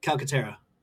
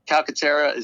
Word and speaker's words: Calcaterra: Are they going to throw his Calcutra? What's his name? – Calcaterra. – Calcaterra, is he? – I'm the one that's usually butchering their names.